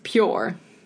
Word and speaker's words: PUR: It's pure. – Pure. No, P-U-R. – I would still pronounce it pure.